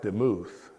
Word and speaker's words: demuth: On the other hand, likeness, the word likeness in Hebrew is the word, it's pronounced demuth, D-E-M-U-T-H, but it's actually D-A-M-A-H, demuth.